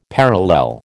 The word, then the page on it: parallel: American English